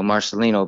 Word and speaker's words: Marcelino: They didn't even call me, you know, Marcelino, because they couldn't pronounce it.